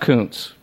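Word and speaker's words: Kuncz: It's K-U-N-C-Z, and it's kind of, I'm sure it doesn't sound, okay. – It's pronounced Kuntz, but it Kuntz. Sounds like it could be something else.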